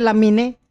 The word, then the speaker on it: Lamine: Se escribe Lamine y se dice Lamin Yamal.